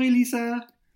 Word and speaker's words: Elissa: So growing up, the way that I heard my name pronounced was Elisa. – Bonjour, Elisa. – Oui, exactement comme ça. So like in English, the closest approximation would be like Alisa.